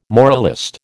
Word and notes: moralist: American English

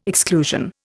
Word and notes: exclusion: Indian

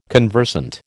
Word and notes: conversant: American English

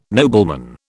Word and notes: nobleman: British English

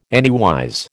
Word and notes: anywise: American English

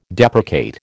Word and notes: deprecate: American English

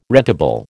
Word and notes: rentable: American English

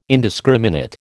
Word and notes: indiscriminate: American English

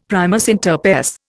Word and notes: primus inter pares: Indian

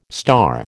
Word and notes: star: American English

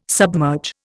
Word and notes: submerge: Indian

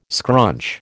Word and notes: scrunch: American English

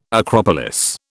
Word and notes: acropolis: British English

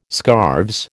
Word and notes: scarves: American English

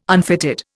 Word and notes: unfitted: Indian

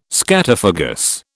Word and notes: scatophagous: British English

